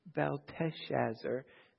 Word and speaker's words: Belteshazzar: Daniel's pagan name is Belteshazzar. This king is Belshazzar. So almost the same, but you notice the extra syllable.